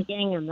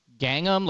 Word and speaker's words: gingham: Like gingham. How do you say it? – Gingham. – Gangnam, like Gangnam style? Like that song that's a Korean? – No, with an I. Gingham. It's a material.